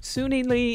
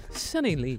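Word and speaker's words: Suni Lee: Sunny Lee, it's somewhere in between there, is the actual right pronunciation that I can't quite do. Sunny Lee. – Both are acceptable.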